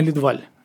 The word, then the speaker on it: Лидваль: А, ваш репортаж вот, «Идеальный Лидваль». Я правильно ударение ставлю? Все, замечательно. Вот,